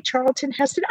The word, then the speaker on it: Charlton Heston: Did I say that right? Charlton Heston. Oh, there he is. – Charlton Yay! Heston, I believe, right? – Yes.